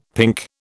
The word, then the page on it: pink: British English